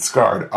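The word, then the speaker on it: SARD: I understood it's S-A-R-D, but we pronounce it Scarred.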